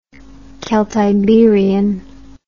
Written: celtiberian

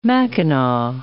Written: mackinaw

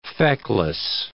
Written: feckless

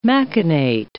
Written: machinate

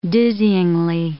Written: dizzyingly

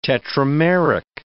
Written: tetrameric

